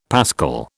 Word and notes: pascal: British English

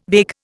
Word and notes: vague: Indian

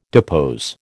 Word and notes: depose: American English

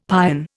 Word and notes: pion: Indian

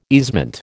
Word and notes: easement: American English

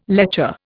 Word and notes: lecher: Indian

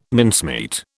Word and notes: mincemeat: British English

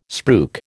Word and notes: spruik: American English

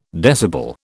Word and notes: decibel: British English

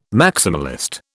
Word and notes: maximalist: British English

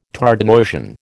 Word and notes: Tardenoisian: American English

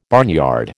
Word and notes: barnyard: American English

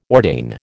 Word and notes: ordain: American English

